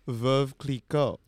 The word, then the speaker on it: Veuve Clicquot: Veuve Clicquot.